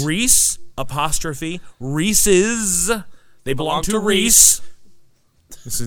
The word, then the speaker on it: Reese's: They are called Reese's peanut butter cups. Alternately, the candy-coated bite-sized peanut butter bites are Reese's Pieces. They were named thusly because Reese's rhymes with pieces. They are not Reese's.